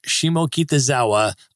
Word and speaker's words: Shimokitazawa: And now, Becky, I want to ask you about your new book, Shimokitazawa, A Tokyo Beginner's Guide to the World's Most Walkable Neighborhood.